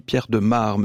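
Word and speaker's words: Pierre de Maere: Je pense à vous, le chanteur belge Pierre de Mare. Oui, on dit Pierre de Mare, me dit Isabelle Fiot. Ça s'écrit M-A-E-R-E. On a tendance à dire Pierre de Mer, mais c'est Pierre de Mare qui sera d'ailleurs en concert à l'Alhambra le 23 janvier 2027.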